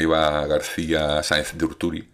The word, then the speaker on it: Eva García Sáenz de Urturi: Pero bueno, el caso es que Eva García Sáenz de Urturi, ¿eh? ahora ya lo he dicho bien, porque lo he buscado,